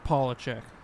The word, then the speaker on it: Polachek: Is it like a long O or a short O? Is it Polachek? – It's just Polachek. – Polachek, okay. – It's like a fucking regular, like, no accent American as much as possible.